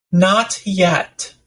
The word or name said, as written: not yet